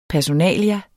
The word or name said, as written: PERSONALIA